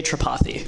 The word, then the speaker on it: Tripathy: It's Ajay Tripathy. – Ajay. Okay, Ajay Tripathy. Yeah. All right. I just have to put the emphasis on the other syllable.